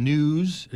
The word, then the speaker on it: Knewz: This is a headline in News. It's spelled K-N-E-W-Z.